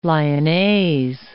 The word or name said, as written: lyonnaise